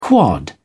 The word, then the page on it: quad: British English